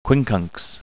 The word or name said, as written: quincunx